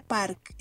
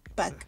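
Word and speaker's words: Bak (박): Park, pero no es, no se pronuncia Park, es Back Back. ajá, Bak. Es con B. Está difícil, pero es Bak.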